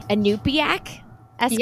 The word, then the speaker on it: Inupiaq: And you shared that, let me see if I can pronounce this correctly, Inupiaq Eskimo? – Yes.